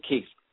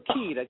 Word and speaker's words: Keith: I'm the only one named Keith. I lived in Italy for 4 years. The Italians could not pronounce the TH. So they go, Kita, Kita, Kita.